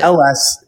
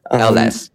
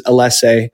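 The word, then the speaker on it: Ellesse: So it's LS, pronounce the letters LS. – LS. Yep, but yeah. – English. – Yeah. And the slang in Europe is Alesse, which is, the same thing. So either or works.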